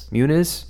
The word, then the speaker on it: Muniz: How do you pronounce his name? I've never known. – Muniz? Yeah.